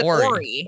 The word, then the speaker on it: Horry: Do you pronounce the H? – Horry? Or is it Horry? – Horry. – Is that a silent H? – Yeah.